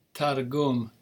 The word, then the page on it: targum: Hebrew